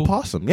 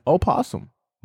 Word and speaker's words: Opossum: Opossum, yeah. – Yeah. – Opossum. But the O is silent for whoever was like, I'm going to put an extra letter here.